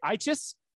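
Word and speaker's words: Eachus: Shane, it's spelled E-A-C-H-U-S. I'm going to say Eichus. Shane Eichus down at Houston Baptist.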